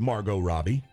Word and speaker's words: Margot Robbie: Those certain people include Margot Robbie.